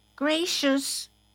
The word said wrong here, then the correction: Gracias: No, say it right. Gracias. Gracias.